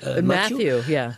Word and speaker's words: Matthew: Matthew.